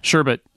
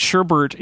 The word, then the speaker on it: sherbet: I understand that it is, but like when I grew up, S-H-E-R-B-E-T. – That's the correct way to say it. Sherbert is not a word. It is a mispronunciation of sherbet.